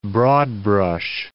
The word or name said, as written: broad-brush